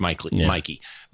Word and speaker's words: Meike: And then this happened at the Mikey booth. M-E-I-K-E. Mm-hmm. I believe it's pronounced Mikey.